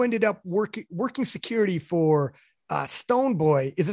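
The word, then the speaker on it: Stonebwoy: Stone Boy. It's just Stone Livingstone. So Stone Boy. – Stone Boy. Okay. Cause I seen a W in there, and I'm like, oh, I wonder if I'm pronouncing it right.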